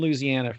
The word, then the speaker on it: Louisiana: You know, I lived in Louisiana for 3 years, and there's definitely only four syllables in that word. If you say it right.